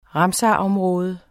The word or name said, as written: ramsarområde